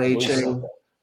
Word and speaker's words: Achane: It was a 55-yarder from – how do you say his name? Devon Achane? Is that? – Yep. Okay. – Devon Achane.